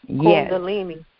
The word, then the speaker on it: Kundalini: And you're seeing people now believe in spirituality and this new age and Kundalini. I don't even know how to pronounce it. Crystals and energy and it's vital Kundalini. – In this season. – It's called Kundalini.